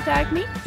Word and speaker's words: Dagny: Do you pronounce it Dagny? – Yeah. What? How would you have pronounced it? – Dagny? Sorry. Like lasagna.